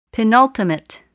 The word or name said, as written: penultimate